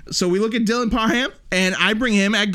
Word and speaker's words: Parham: I'm looking at Dylan Perham. I think that's Perham, right? That's how you say it. – I would say Parham. – Is it Parham? Parham.